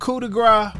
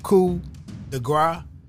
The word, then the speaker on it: coup de grâce: One, I wanted to start with the definition of coup de grace. It does say here that the P is silent.